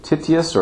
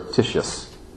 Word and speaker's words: Titius: Are they pronouncing it Titius or Titius? – Both. – Okay. – Both acceptable.